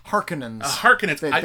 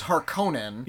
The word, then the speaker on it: Harkonnen: Harkonnens. They changed the pronunciation. – In David Lynch's, it was Harkonnen. – Yeah. – In this one, they say Harkonnen.